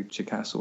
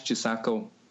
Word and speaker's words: Chisako: I always say Chikasa or Chisako. Dash Chisako. I like Sendai Girls.